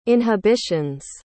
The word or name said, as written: inhibitions